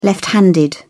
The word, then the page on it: left handed: British English